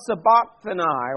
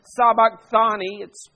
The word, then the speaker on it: sabachthani: And at the ninth hour, Jesus cried with a loud voice, Eloi, Eloi, lama sabachthani, or sabachthani. I've heard it pronounced both ways.